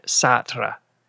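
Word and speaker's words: seter: Sissel seems to pronounce it satra, and it's S-E-T-E-R in English, at least.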